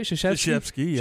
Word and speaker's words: Krzyzewski: Gentlemen, did you know that today is Coach Mike Krzyzewski? Is that how you say it? – Krzyzewski.